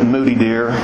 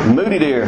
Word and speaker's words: Moutardier: Locals call it Moody Deer. It's spelled, the French words are devilish. It's spelled M-O-U-T-A-R-D-I-E-R, Moody Deer. In Hart County, there's a place on the Green River spelled R-I-O, as in the Rio Grande.